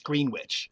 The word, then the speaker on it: Greenwich: But there are three Greenwich townships in New Jersey, pronounced Greenwich.